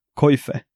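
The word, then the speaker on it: coife: Och då visade det sig dels att det är ett E på slutet. Och det E-et är inte stumt. Och dessutom, uttalet blir som det stavas då. Koife.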